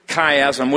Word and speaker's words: chiasm: And it comes from the Greek word chiasm, which is beginning not with a C-H the way we would spell it. We spell it C-H-I-A-S-M. They would spell it with an X, key.